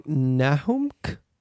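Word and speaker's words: Nahumck: So Tim, and I'm going to get his last name wrong, Nahumk? I'm not sure how to say this last name. How would you say, Mike? – Like you just did.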